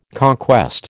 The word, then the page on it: conquest: American English